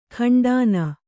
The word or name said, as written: ಖಂಡಾನ